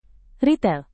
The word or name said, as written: ritel